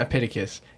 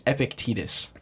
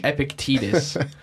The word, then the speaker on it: Epictetus: Like I listened to the one by Epictetus. – Yeah. – the Discourses of Epictetus or something. – I don't know that one. – It's not Epictetus. It's Epictetus.